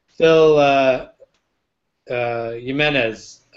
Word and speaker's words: Phil Jimenez: I remember way back during Infinite Crisis, Phil Jimenez.